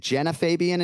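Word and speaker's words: Genah Fabian: So if my research is accurate, you were to face, is it Jenna Fabian in 2019? Okay. Yep. I pronounced her name right.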